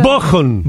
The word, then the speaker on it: Bochum: Bochum.